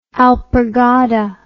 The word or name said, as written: alpargata